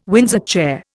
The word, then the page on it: Windsor chair: Indian